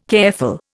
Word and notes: careful: Indian

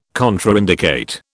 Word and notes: contraindicate: British English

